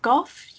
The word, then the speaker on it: Goff: So basically everything I've said is a form of religious fictionalism, and I really admire Philip, is it Goff you pronounce yes.